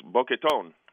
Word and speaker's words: Boketon: And it's spelled Boketon, and they really emphasize the last three letters. So a lot of his Dominican friends will just call him Tone. – Okay. – But it's Boquetón.